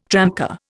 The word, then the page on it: tramcar: Indian